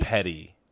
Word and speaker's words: petit: Petty. That's how you pronounce that. – No. – Yeah, petty retail theft. – I thought petty was P-E-T-T-Y. – Me too. My whole life until I learned this. No, it's petty, like P-E-T-I.